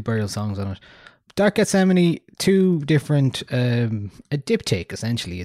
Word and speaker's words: Gethsemane: Gethsemane. Like Yosemite.